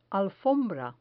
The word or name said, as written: alfombra